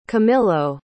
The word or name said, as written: camillo